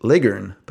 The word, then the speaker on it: Leghorn: A Leghorn chicken is pronounced Leghorn, but it doesn't rhyme with Foghorn, so never mind.